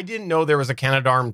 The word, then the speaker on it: Canadarm: It's actually Canadarm. It's Canadarm. It's not Canada Arm. It's Canadarm. So I've talked about it before, about how it was an important thing for the space shuttle.